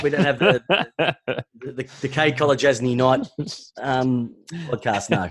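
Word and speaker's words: Kolodjashnij: what's his name again? I can't pronounce it. – Who? – Colajasny. That's his name.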